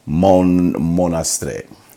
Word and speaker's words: Monastrell: So it's spelled M-O-N-A-S-T-R-E-L-L. Monastrell. So it sounds like a Monastrell, but it's a Monastrell. But they also have in France, it's called a Mouvedé. But we're talking about a 30% Monastrell.